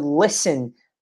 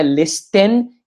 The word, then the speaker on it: listen: Então, aqui nesse caso, she likes to listen. Presta atenção na, na pronúncia dessa palavra, não é listen, e sim listen. Tira o T.